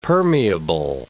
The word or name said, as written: permeable